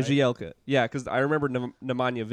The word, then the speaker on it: Bjelica: How do you even say his name? – Bjelica. – Bjelica. Oh, my gosh. I knew that, too.